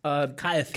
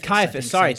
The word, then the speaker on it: Caiaphas: Caiaphas. – Caiaphas, Thank you. I don't know why I said that wrong.